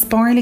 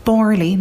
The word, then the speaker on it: Barley Cove: Do you know if there's a campsite in West Cork where they segregate people based on the way they pronounce Barley Cove? Jenny, Douglas Road. Oh, and it's Borley, not Barley.